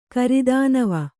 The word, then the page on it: ಗಿರಿಧನ್ವ: Kannada